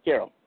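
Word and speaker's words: Qerim: How do you say it? – Yep. – Quirm? – Karam.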